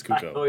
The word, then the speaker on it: Cuoco: Kaylee Cuoco, I think it is. – It's Cuoco.